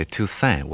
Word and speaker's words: Toussaint: My father used to say Toussaint without a T on the end.